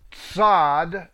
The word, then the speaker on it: Tzad: Number 18 is Tzad. That's a TS sound. Number 19 is Kof, Q-O-P-H, with a Kof.